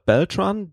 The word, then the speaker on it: Beltran: Und da war Robert Beltran, der Darsteller von Chakoti bei Voyager. Ich hoffe, ich habe das jetzt richtig ausgesprochen mit Bel Beltran heißt der, ne? Ja, genau.